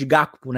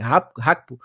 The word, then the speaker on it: Gakpo: Ele já utilizou o, o Hakpo, né? Que chamam de Gakpo, né? Mas é a pronúncia certa é Hakpo.